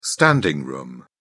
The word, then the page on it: standing room: British English